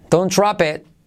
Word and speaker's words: Don't drop it: Drop y la T de it casi no se dice. Don't drop it. Don't drop it. ¿Veis? Don't drop it, que no se te caiga. Y hay que decirlo rápido.